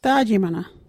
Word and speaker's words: Thaajimana: Our local Wajiri word is Dajimana.